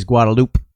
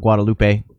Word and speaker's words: Guadalupe: Kind of like in the road, the main drag in Austin is Guadalupe, not Guadalupe.